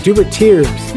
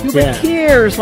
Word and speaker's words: Dubertiers: Dubertiers. – Dubertiers, like musketeers without the big ears.